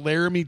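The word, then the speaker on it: Laremy: Let's talk about that quickly about that Laramie Tunsil situation. – First of all, what a fucking weird name is Laramie. – Laramie Tunsil? – Yeah, it's not even spelled like Laramie.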